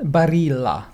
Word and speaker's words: Barilla: Barilla. – Barilla. – Okay. In Italian, you pronounce the L's.